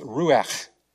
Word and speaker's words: ruach: When you look at the Hebrew word for breath, it's a very breathy sounding word. It's ruach. Yeah, I didn't have phlegm. That's actually how you pronounce the word.